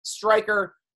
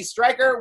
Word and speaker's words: Stryker: Stryker, at SNStryker on Instagram and Twitter. Stryker is spelled with a Y.